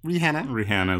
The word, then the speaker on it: Rihanna: Category is, so your girl, this is your girl, Rihanna is how you pronounce her name.